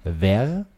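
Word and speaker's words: verre: Ver, V-E-R-R-E, e bedeutet Glas. Und das Spannende, es gibt ein zweites französisches Wort, das spricht man eigentlich genauso aus, auch ver, das schreibt man aber V-A-I-R und das bedeutet Eichhörnchenfell.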